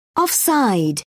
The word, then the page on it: offside: British English